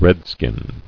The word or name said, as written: Redskin